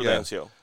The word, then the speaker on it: Prudencio: You did it's soft D, right? – Yeah. – You don't want to Prudencio.